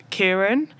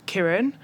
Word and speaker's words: Kiran: All my life, from a young age, since being at school, I've always been called Kiran. And it's only recently that I've started correcting people and saying, actually, my name's pronounced Kiran. And they're two completely different names, completely different meanings.